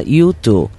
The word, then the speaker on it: U2: Y para este retro elegimos la banda que se escribe con letra y número y se pronuncia U2, la banda más destacada del rock alternativo de Irlanda, formada en 1976.